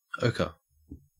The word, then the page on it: ochre: Australian English